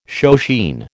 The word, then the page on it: shoeshine: American English